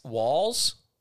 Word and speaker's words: Walz: Okay, Governor Tim Walz. Walz. W-A-L-Z. How would you say it? – Yep. – Speaking of radio stations. Governor W-A-L-Z.